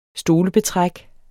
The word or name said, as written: stolebetræk